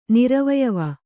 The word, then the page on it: ನಿರವಯವ: Kannada